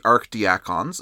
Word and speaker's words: archdiacon: And no, I haven't forgotten how to pronounce archdeacon. It just so happens that in this cultural context, that title is pronounced as archdiacon,